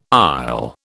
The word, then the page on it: aisle: British English